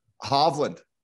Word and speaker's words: Hovland: Hovland.